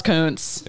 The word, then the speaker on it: Kuntz: Number 4, I'm going to pronounce it how it looks, and then I'm going to say it's probably this, but it looks like Rusty Kuntz. But I'm sure the last name is pronounced Kuntz. – Yeah. – Kuntz. But it's K-U-N-T-Z. – Oh, now you're going to feel bad.